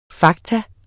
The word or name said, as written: faktum